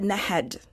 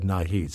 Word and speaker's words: Nahed: Is it Nahid or Nahid? – She said Nahed. I knew Mrs. Chapman. She was like a sister Nahed. – To me. – Okay. – So she always pronounced her name as Nahed.